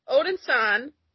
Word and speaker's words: Odinson: Son. son, not son. Eric Odinson Son. son. you were right there. It's like it's like you want to pronounce it right, but your mind blows. It's like Odin son.